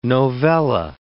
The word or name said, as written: novella